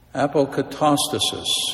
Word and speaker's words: apokatastasis: apokatastasis, okay, the restoration of all things.